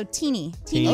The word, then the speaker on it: Tini: You know, my name is not phonetically spelled, but for some context, my full name is Christine. So if you just drop the Chris, add a Y, can't lose. So Tini okay.